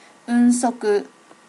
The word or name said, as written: unsoku